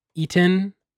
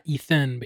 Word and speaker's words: Etan: it's Eaton, not Ethan, but E T A N, Boritzer.